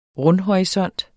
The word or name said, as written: rundhorisont